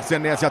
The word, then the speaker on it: Senesi: A gente vai de Marco Senesi ou Senesi, a pronúncia correta? – Senesi, Senesi. Senesi, então o narrador estava falando certinho, né? – E o Anderson também.